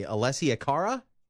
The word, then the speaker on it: Alessia Cara: Alessia Cara? – Sure.